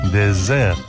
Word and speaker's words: désert: En francés, postre es dessert y desierto es désert. La diferencia consiste en que désert, desierto, se pronuncia con una S sonora, como la que antiguamente tenía el castellano, pero ya no, y que, por ejemplo, siguen teniendo en su sistema fonológico el catalán valenciano o el portugués.